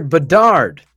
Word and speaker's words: Bedard: We quote, we shouldn't have drafted Connor Bedard.